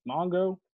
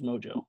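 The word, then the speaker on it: Mongeau: Is it Mojo? Mongo? How do you say it? I think it's Mojo. – Mojo. – It's definitely not Mongo.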